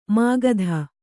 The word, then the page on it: ಮುಗ್ಧ: kannada